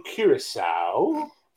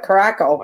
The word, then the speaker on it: Curaçao: I'm just glad I know how to say it right. I used to call it Caracol.